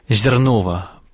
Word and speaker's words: Зернова: Житие священномученика Евгения Зернова». Правильное ударение ставлю? – Правильно, да.